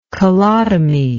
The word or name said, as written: colotomy